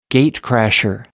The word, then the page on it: gate crasher: British English